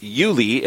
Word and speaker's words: Euli: So your book is titled, it's a Christmas book, titled Yuli, and that's spelled E-U-L-I, Yuli, The Little Boy Who Loves Christmas, and based on a true story.